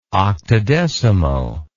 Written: octodecimo

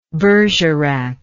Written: bergerac

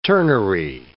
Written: turnery

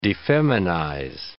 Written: defeminize

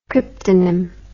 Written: cryptonym

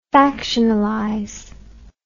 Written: factionalization

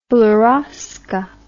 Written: beryozka

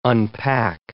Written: unpack

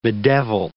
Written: bedevil

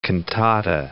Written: cantata

